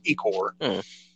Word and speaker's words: Ichor: And then, you know, he's like, yeah, so if we help them and we let them keep the body, and he called it Ichor, but I've always heard it pronounced Ikor.